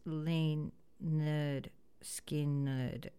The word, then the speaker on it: Lynyrd Skynyrd: Simple Man and Free Bird, in regards to the 53-year anniversary of Lynyrd Skynyrd releasing their debut album, pronounced Lynyrd Skynyrd.